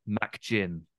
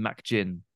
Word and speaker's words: McGinn: But one thing I can't get out of my head is the way they pronounce McGinn. – Right. – They call him McGinn. – Okay. Right. – So there's an emphasis on Mac, I think probably from McAllister.